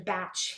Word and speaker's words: Bach: The so-called Bach flower essences, although Dr. Batch himself pronounced his name Batch, so they really are meant to be called the Batch flower essences, they're very nice.